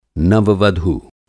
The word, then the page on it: नव-वधू: नववधू; Hindi